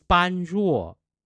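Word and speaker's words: panruo (般若): Prajna, panya, becomes panruo. You know, have you heard of panruo in Chinese? It's really the Mandarin pronunciation of the older panya.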